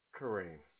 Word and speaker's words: Kareem: Kareem. – Could you spell that just to make sure? – Sure, K-A-R-E-E-M.